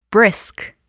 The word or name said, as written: brisk